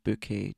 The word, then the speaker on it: Bucket: The main character in it is called Hyacinth Bouquet, although it is spelled B-U-C-K-E-T, but she pronounces it Bouquet, dear.